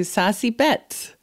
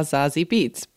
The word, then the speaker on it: Zazie Beetz: Yes, today we're talking to Zazie Beetz, who some people call Zazie Beetz, but I went down the rabbit hole and she says her name in the interview. And she's half German, so it's a German name and that's how you pronounce it.